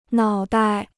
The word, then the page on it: 脑袋: nǎo dai